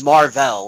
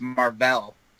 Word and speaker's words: Mar-Vell: And then it comes out, this is Mar-Vell. Like, this is it. Like, yeah. She is the lady. – Jackson going, Marvel. And she's like, no, it's Mar-Vell. And he's like, yeah, Marvel. That's how it's spelled.